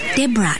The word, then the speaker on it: Debra: Now that you've got the name roughly right, it's Deborah, D-E-B-R-A. – Oh, the O is silent. – There is no O to be silent.